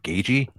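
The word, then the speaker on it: Gaethje: Yeah, and he comes at you from all kinds of different angles, which will be interesting to see how I can't say his name right. Gagey, is that right? – Yeah, it doesn't look like it sounds like Gagey, but yeah, that's what it is.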